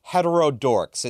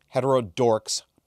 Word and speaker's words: Heterodorx: If you want to hear more from Corinna and from Nina Paley, they have a podcast they host together called Heterodorks. It's spelled H-E-T-E-R-O-D-O-R-X. Heterodorks podcast.